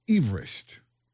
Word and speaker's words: Everest: The correct pronunciation is Everest. That's what us colonizers call it anyway. It's not what the people in the area call it. But Sir George Everest was a surveyor from Wales. It's named after him, and that's how he pronounced his name.